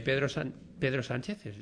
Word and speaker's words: Pedro Sánchez: A mí me parece que Pedro Sánchez, lo he dicho bien.